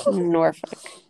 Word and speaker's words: Norfolk: If I pronounce Norfolk, am I pronouncing it wrong? – I'm back. I don't know why my phone would keep locking when I'm, like, anyway. You are pronouncing it the way that it is spelled and the way that I said it before I moved here. But I guess now I say it like the locals. Norfolk.